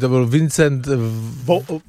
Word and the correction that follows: Vincent Vaughn: Nie. Vincent Vaughn, nie?